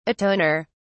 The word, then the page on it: atoner: British English